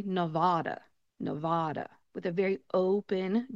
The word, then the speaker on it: Nevada: When you say this state name, you are going to say Nevada. Nevada.